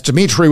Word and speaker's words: Dimitri: That's Dimitri with three I's, D-I-M-I-T-R-I.